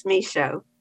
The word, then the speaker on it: Michaud: And Anne, your last name is pronounced McCaud, is that correct? – Nope. – No, please pronounce that for me. – The D is silent, it's Michaud.